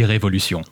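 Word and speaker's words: Révolution: Technically, you can say both, but the way it's spelled is the French way, so it would be Revolution.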